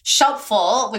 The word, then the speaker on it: Shelpful: So Shelpful, which rhymes with helpful, is an instant accountability buddy service.